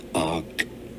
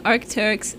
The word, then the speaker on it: Arc'teryx: Arcturus. – What? Is that not what I said? – No, we're saying Arc'teryx, and then you're saying...